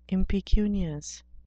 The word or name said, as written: impecunious